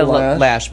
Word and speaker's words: Loesch: Dana Loesch, but it's spelled funky. – Yeah, hang on a second. Let me do this. – L-O-E-S-C-H.